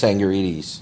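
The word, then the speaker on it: Tsangarides: Chris Sangarides.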